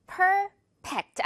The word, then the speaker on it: perfect: 처음에는 per, 우리 P처럼 발음을 하기 시작해서, 두 번째로는 토끼 이빨. Perfect 해줘야겠죠.